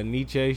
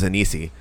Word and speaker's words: Nietzsche: I'm pretty sure that was a Nietzsche shirt I had on. – Fuck it. Is that how you pronounce it? I always thought it was Anisi. – No.